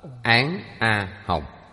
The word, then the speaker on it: Án A Hồng: Ba chữ này âm đọc là Án A Hồng.